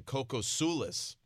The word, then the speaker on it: Kokosoulis: Kokosoulis.